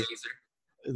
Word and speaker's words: Elieser: Well, Eliza. Hern- I can't ever say his name. Hernandez. – Elieser. – Yeah, whatever. Elieser. Elieser. That's all I think was laser like the shark with laser hair.